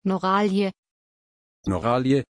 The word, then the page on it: Noralie: German